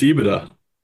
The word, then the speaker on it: EBITDA: It's EBITDA.